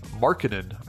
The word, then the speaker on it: Markkanen: Markkanen. I just learned I've been saying that wrong.